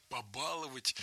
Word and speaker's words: побаловать: Побаловать – это тоже сейчас правильный вариант, наряду с вариантом «побаловать». Раньше требовалось в глаголах с корнем «бал» ставить ударение только за корнем. Но сейчас возможны варианты «баловать» и «баловать», «побаловать» и «побаловать».